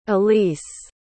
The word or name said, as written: elease